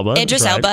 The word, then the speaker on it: Idris Elba: saying things like Idris Elba. I always mispronounce his name. – Idris Elba. Right.